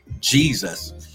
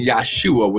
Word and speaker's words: Yahshua: There's no J in the Hebrew language, so his name would not have been pronounced Jesus. It would have been Yahshua with a Y, Yahshua.